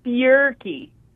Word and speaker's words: Bjerke: Now, you have the word beer in your name, so I like you already. – No, it's Bierke. – Bierke. Now, it's spelled B-J-E-R-K-E, which is Norwegian.